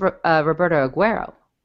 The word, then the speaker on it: Roberto Aguayo: How do you say his name again? – I believe it's Roberto Aguayo. – Aguayo.